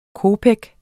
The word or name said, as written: kopek